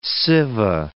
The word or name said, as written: Siva